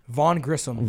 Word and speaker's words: Grissom: But back to, like, how did you say his name? Vaughn Grissom? – Vaughn Grissom.